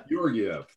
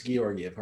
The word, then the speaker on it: Georgiev: It's a hard G, not a soft G, as much as they call him Georgie, because when he first came up, we all thought it was Georgiev, but so they call him Georgie, but no, it's Georgiev, hard G.